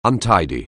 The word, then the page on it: untidy: anglais